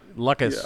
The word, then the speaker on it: Lukas: Like, My name's spelled, my real name, it's the reason I go by Luke instead of my real name, which is Lucas, is because it's spelled L-U-K-A-S, and I've just got tired of people spelling it wrong. – Lucas? – Yeah. Lucas.